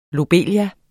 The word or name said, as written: lobelie